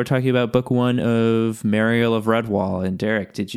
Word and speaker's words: Mariel: The audiobook makes it pretty clear that it is Mariel because it sounds like a bell.